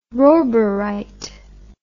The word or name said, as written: roburite